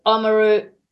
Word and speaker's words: Oamaru: Timaru, Oamaru, if I'm pronouncing that right, Rodney. – Yep. – Oamaru, Dunedin, Invercargill, Tiarnau.